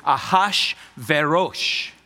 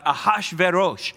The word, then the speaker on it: Ahasuerus: The Persian name of Xerxes is Ahasuerus. So I was close, not completely on it, but Ahasuerus.